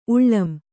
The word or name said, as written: ಉಳ್ಳಂ